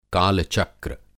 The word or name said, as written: काल-चक्र